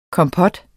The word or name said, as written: KOMPOT